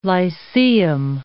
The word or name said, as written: lyceum